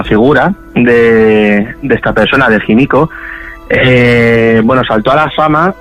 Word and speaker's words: Himiko: Himiko, Himiko. – Sí, sí, bueno, en español más Himiko, sí, podemos pronunciarlo así.